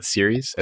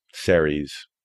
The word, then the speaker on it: Ceres: You call it series? – Series. The Roman goddess, not... – Oh, with a C. Mm-hmm. – Yeah. – Ceres. Ceres. Our team pronounces it both ways.